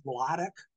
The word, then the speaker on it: Wladek: His first name was always pronounced Wladek, Killer Kowalski.